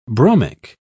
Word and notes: bromic: British English

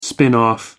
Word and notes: spin-off: American English

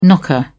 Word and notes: knocker: British English

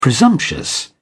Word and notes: presumptuous: British English

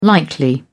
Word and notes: likely: British English

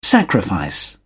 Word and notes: sacrifice: British English

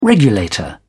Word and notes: regulator: British English

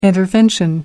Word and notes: intervention: British English

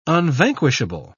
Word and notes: unvanquishable: British English

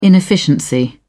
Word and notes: inefficiency: British English

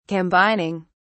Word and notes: combining: British English

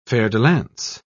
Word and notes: fer-de-lance: British English

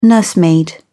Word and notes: nursemaid: British English

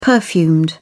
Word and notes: perfumed: British English

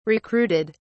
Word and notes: recruited: British English

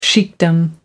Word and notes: sheikhdom: American English

